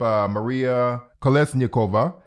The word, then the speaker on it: Maria Kolesnikova: So the leadership, Maria Kolesnikova, Svetlana Tsikhanouskaya, and Veronika Stepkala, those are the three opposition leaders, and I pray that I did not butcher their names.